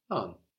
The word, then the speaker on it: un: No, so for one, we have un, like in un peu. No, that reminds us of how the N gets swallowed into that U and how it changes the pronunciation.